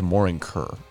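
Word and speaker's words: Morin Khuur: The two-stringed horsehead fiddle, pronounced Morin Kur,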